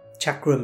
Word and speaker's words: chakra: Chakra means wheel, and by the way, it's pronounced chakra because it's an Indian word.